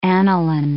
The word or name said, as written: aniline